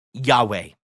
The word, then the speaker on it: Yahweh: The Hebrews did not write vowels in those days, so we add them based on an understood pronunciation and call his name Yahweh.